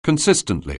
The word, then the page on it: consistently: anglais